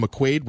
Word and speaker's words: McQuaid: and they acquired Adam McQuaid, which rhymes with Keith Kincaid.